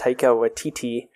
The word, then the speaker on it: Taika Waititi: So all the people living in this house are vampires, and it stars Jermaine Clement and Taika, I think it's pronounced Taika Waititi.